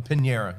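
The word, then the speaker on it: Piñeiro: From New York Times bestselling author, Caridad, how do you say the last name? – No. – Pinero. – A Pinera, yeah.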